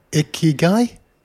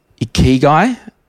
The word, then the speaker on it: Ikigai: Ikigai? – Ikigai, the Japanese secret to long and happy life.